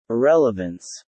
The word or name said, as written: irrelevance